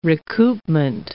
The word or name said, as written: recoupment